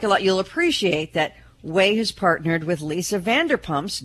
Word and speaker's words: Ouai: She's now selling a pet shampoo from Way. Way is spelled, by the way, O-U-A-I, hair care products.